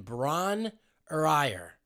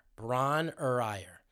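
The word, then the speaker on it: Bron-Yr-Aur: At the suggestion of Robert, he and Jimmy retreated to the country and took up refuge in Bron or Ire. That's how it's pronounced. Bron-Y-Aur. Bron or Ire.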